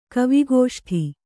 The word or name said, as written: ಕವಿಗೋಷ್ಠಿ